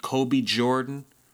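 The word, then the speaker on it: Kobe Jxrdan: Then we had I Got a Jumper, a song by Kobe Jordan, but it's spelled J-X-R-D-A-N. Kobe Jordan.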